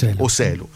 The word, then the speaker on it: Joselu: è quello del 34enne Joselu, come si pronuncia? – Joselu.